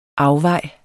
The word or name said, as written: afvej